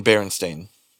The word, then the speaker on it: Berenstain: It's always been Berenstain. like Stain, not Steen.